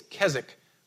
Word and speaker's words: Keswick: I think it's Keswick, but it's spelled K-E-S-W-I-C-K, Keswick teaching.